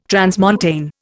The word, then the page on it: transmontane: Indian